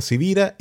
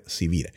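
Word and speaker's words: Sivira: Mi nombre es Carlos Javier Civira y mi apellido no es tan probablemente común, así que se escribe con S de semana y con B de Victoria.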